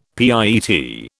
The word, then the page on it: pietà: British English